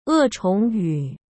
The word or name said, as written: E Chongyu